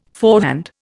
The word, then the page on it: forehand: Indian